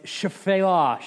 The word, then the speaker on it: Shephelah: It looks like Shephelah. It's Shephelah, Shephelah. The, the, first E is barely even pronounced.